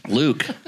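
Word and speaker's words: Luc: Luck? – Luke. – What? – Luke. – I love it. – It's Luke. – It's L-U-C. – Yeah, that's Luke. It's the European spelling of Luke.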